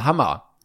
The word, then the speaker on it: Hammer: Aber das Problem ist, wenn du dich Hammer nennst, dann werden ja alle denken, du heißt Hummer. Und dann musst du jedes Mal sagen, nee, Englisch ausgesprochen. Nee, nicht Hummer, Hammer.